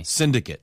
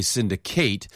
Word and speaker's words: syndicate: So many words like this change pronunciation depending on grammar. – 그렇죠. – If you want to use the verb form, then yeah, I would say syndicate. 네. But as a noun, it is syndicate.